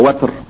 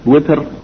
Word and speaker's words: Witr: Bab al-Witr, or Watr, as some of the scholars said, that the word may be pronounced with kasra or with fatha. Witr, which is the more common pronunciation, or Watr, and it means al-Fard, or something single.